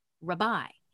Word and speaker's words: Rabai: So the correct pronunciation of my last name is Rabai. – Rabai. – It's Italian. Mm-hmm. I'm a paisan. So if it was with an Italian accent, Rabai, Rabai, right?